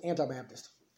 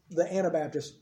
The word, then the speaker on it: Anabaptists: Me and the girls were talking about this last night, the Anabaptists. They kept trying to pronounce it Anti-Baptist. I said, wait a minute, that means something completely different.